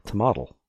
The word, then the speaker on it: tomatl: And it was originally an Aztec word, Nahuatl, and it was tomatl. – T-O-M-A-T-L.